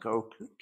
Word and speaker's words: Koch: K-O-C-H? How do you pronounce that? I always read it, but I never hear it. Koch? All right, it's pronounced like Coke, K-O-C-H.